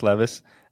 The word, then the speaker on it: Levis: I can't tell if Will Levis, now that we've learned how to say his name, it's not Levis, Levis, is good for Tyjae Spears or not.